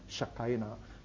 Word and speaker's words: Shekinah: And that presence, that glorious presence, is described in Hebrew as the Shekinah. So Shekinah. So in English, when anglicized, we pronounce it as Shekinah.